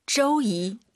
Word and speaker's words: 周一: 周一. – 오유 발음은 어우예요. 절대 쪼쪼 하지 마세요. 쪼, 쪼이 이렇게 발음하는 거예요.